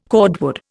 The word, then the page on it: cordwood: Indian